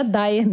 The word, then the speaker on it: Dyer: O nome da Gabriele era Dyer mesmo. D-Y-E-R. Mas como a pronúncia era Dyer, quase toda a imprensa brasileira passou a escrever D-A-I-R. Y-E-R.